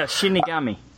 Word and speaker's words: Shinigami: The Shinigami, I don't even know if I'm saying that right, but yeah, Shinigami.